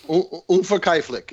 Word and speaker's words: Unverkäuflich: Unverkäuflich is the German.